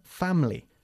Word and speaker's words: family: Aunque se escribe family, la pronunciamos family. – Family. – Ok. Nos comemos casi la I latina del medio.